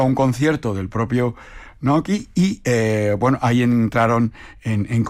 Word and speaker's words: Naoki: And she was playing, ella estaba actuando, y un amigo suyo, un ingeniero amigo de Naoki, pues estaba, tenía, Nakoi, perdón, estoy pronunciándolo mal, Naoki.